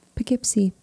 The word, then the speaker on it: Poughkeepsie: Poughkeepsie. That's pronounced right too.